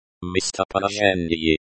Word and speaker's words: местоположение: Pronounced местоположение, stress on the third syllable.